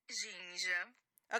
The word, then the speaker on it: ginja: And the proper way to say it is ginja. – Okay, yeah, I totally did not nail that at all.